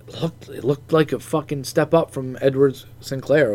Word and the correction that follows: Edwards-Helaire: over there. – Oh, Edwards-Helaire? He's hurt, right?